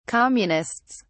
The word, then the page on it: communists: British English